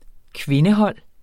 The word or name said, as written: kvindehold